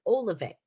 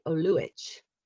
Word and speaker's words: Oluvic: So that's my husband's family is Croatian. So now when you're in Europe, you would say it, you would pronounce it Oluvic. – So, Oluvic. – Here in America, it's Oluvic.